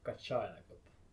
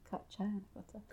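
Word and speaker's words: Kaccānagotta: Like the Pali pronunciation would be Kacchayanagota. – Kacchayanagota.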